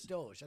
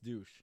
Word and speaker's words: Doge: I think it's Doge. I think Doge. It's pronounced okay. Doge. – I thought it was pronounced douche.